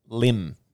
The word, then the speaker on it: Lymm: He's got a profile on something called, how would you pronounce that? – Lim. – Lim Radio. Phil Roberts. – L-Y-M-M.